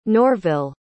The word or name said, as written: norville